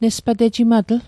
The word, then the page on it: nespatejimatl: Mi'gmaq